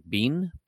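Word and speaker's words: Biehn: Michael, I always say his last name wrong. Bean. Michael Bean.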